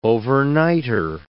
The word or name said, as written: overnighter